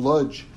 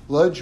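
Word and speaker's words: Łódź: So Rabbi Meisels, Eliyahu Meisels, was a very, very great rabbi in Lodz. Lodz was kind of the richest of the Polish cities. Like, if you see it spelled in English, it's L-O-D-Z. But D-Z is pronounced J in English.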